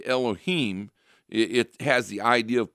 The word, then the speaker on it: Elohim: Elohim, yeah.